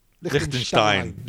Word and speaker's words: Liechtenstein: Liechtenstein.